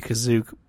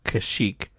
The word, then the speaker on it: Kashyyyk: If we're going by the rules of the holiday special, it would be Kazook, but George Lucas retconned it to be Kashyyyk.